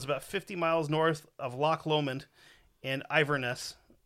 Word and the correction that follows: Inverness: I probably sp- pronounced that wrong, but uh, it's Inverness, sorry.